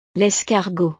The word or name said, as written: l'escargot